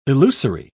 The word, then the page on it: elusory: British English